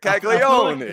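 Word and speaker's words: Caglianone: Caglione, he's starting. He's starting. Oh, did I butcher the name already? – Well, no, if you were in Sicily, that's exactly how you would say it. Caglione.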